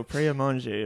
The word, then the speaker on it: Pret-a-Manger: We're going to use the original French. So, Pret-a-Manger, apparently.